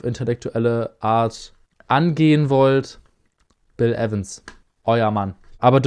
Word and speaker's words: Evans: Bill Evans. – Zumindest wird er halt im Intro verwendet. – Es wird Evans ausgesprochen. – Auf jeden Fall. 2-2-1-2.